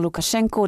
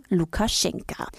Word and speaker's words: Lukaschenka: Seit 1994 ist Alexander Lukaschenko der Präsident. Im Belarussischen wird der Nachname mit einem A am Ende geschrieben und auch ausgesprochen, also Lukaschenka.